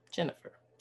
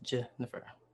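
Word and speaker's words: J'nefer: But you know my name, Jennifer Higgins. It is spelled J with an apostrophe, N-E-F-E-R, on purpose, and it is still pronounced Jennifer.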